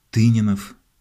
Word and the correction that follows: Тынянов: Я уточнил, правильно говорить, Юрий Тынянов.